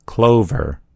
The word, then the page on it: clover: American English